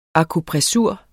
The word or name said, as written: akupressur